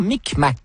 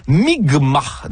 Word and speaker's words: Mi'kmaq: Où Jacques Cartier et son équipage vont être accueillis par des membres de la nation Micmac, qui se prononce en fait Mi'kmaq, dans ce qui sera le premier combat.